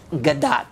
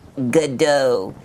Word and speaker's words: Gadot: Oh, spirit, do I have a shot with Gal Gadot? – Wonder Woman? – It is actually pronounced Gadot. Gadot.